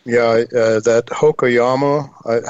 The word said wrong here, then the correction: Hokuryu: how do you pronounce it? It looks like... – Hokuryu. Yeah, Hokuryu is the third target at Omu.